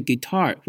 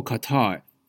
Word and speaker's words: Qatar: 卡达的发音常在媒体上听到的是 guitar 或 Qatar。 Guitar 比较接近当地人阿拉伯语的口音，那 Qatar 就是外国人看字怎么念就怎么读。